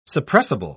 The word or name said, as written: suppressible